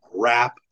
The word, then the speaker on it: wrap: Wrap. I know that's spelled with a W, but you know, it sounds like an R.